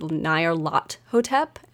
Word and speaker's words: Nyarlathotep: So I know a lot of people say Nyarlathotep, but as I mentioned last episode, given its clearly Egyptian origins, I will pronounce it Nyarlathotep, and that's a hill I'll die on.